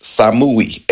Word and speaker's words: Samui: Samui is written in Hebrew and it's pronounced Samui, S-A-M-U-Y.